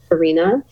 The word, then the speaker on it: Farina: My name is spelled C-H-E-R-I. And F like Frank, A-R-I, and like Nancy, A, Farina is my last name.